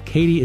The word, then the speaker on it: Caity: Katie is spelled C-A-I-T-Y, and George is spelled G-Y-O-R-G-Y.